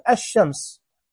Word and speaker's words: الشمس: اللام الشمسية تكتب ولا تنطق، يعني ألف لام تلاقيها مكتوبة في الإملاء، مثلا الشمس، الشمس مو الشمس، الشمس هنلاقيها مكتوبة ألف لام شين، لكن إحنا ما ننطقها، إحنا ننطق ألف شين على طول، نقول الشمس، الشمس، نقول مثلا الذهب، الذهب، وين اللام؟